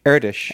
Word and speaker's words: Erdős: Okay, so you actually pronounce it Erdős? – Erdős.